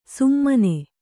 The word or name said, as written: ಸುಮ್ಮನೆ